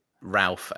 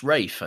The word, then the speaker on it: Ralph: Did you intentionally pronounce it Ralph and Pfeiffer? – Ralph? – It's Rafe, I think. I'm pretty sure it's pronounced Rafe. It's spelled Ralph.